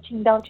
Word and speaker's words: Qingdao: You have an interesting history, one that begins in, I'm probably going to blow this pronunciation, Qingdao, China. – That is correct. You pronounce it perfectly fine. Yes. – Great. – In the lovely coastal city of Qingdao, China.